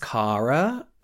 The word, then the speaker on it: Carra: There's like, I believe it's pronounced Cara, C-A-R-R-A.